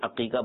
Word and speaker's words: عقیقہ: تو اصل میں حقیقہ نہیں ہوتا, یہ بہت سارے لوگ عقیقہ کو حقیقہ بولتے ہیں, ہا سے بولتے ہیں, تو یہ ہا سے حقیقہ نہیں ہے, عین سے ہے, اقن عین قاف, اس سے عقیقہ بنا ہے, ہا نہیں ہے, تو یہ تلفظ غلط ہے, عقیقہ بولنا چاہیے.